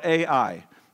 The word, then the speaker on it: Ai: The next battle is the Battle of Ai, which is spelled A-I. I think that's how you pronounce it, Ai.